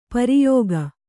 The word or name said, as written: ಪ್ರಯೋಗ